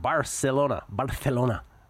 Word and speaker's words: Barcelona: Barcelona, if you pronounce it in the Castellano.